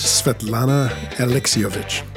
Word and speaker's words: Svetlana Alexievich: Svetlana Alexievich.